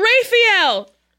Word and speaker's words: Raphael: It's June, Diane, Raphael. I said it correctly. I've been saying Raphael incorrectly. – Okay. – And then Casey corrected me.